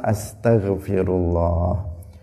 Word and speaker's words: astaghfirullah: Nah, tetapi bacanya yang benar astaghfirullah Bukan astaghfirullah Sebagian orang membacanya asto, nah, bukan asta. astaghfirullah, Nah, roknya di dhammah bukan di sukun karena ada sebagian yang membaca astaghfirullah ada yang membacanya seperti itu.